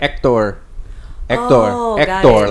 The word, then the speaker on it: Hector: How would you say Hector in Spanish? – Hector. – Oh. – You don't, okay. The H is silent.